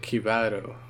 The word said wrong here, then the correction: Kibito: Oh, but can one of you say his name right once? Just Kibito? Just once. – Kibito! – Kibito! – Kibito! – Kibito. – It's Kibito.